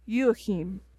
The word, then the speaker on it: Joachim: Joachim.